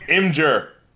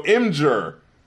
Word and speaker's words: Imgur: And I'm Rap Critic, and it's pronounced Imgur.